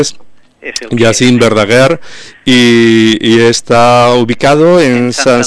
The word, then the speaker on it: Jacint Verdaguer: Estaba dudando al pronunciar el nombre del centro. Es Centre Educatiu. – Sí. – Jacint Verdaguer. – Jacint Verdaguer. – Lo habéis dicho muy bien.